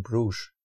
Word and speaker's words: Brugge: It might have been in Brugge, in the right pronunciation, and I've been to a beer house there, and I think they claim to have like all Belgian beers.